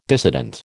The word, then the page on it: dissident: American English